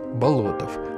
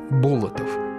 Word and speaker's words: Болотов: Имя Андрей Тимофеевич Болотов. Хотя чаще его фамилию произносят неправильно – Болотов.